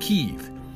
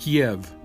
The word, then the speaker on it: Kyiv: Like, for instance, in Ukraine, the city of Kyiv is pronounced Kyiv. In Russian, it's pronounced Kiev.